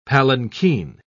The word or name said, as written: palanquin